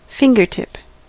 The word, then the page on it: fingertip: /ˈfɪŋ.ɡəɹ.ˌtɪp/